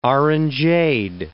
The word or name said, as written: orangeade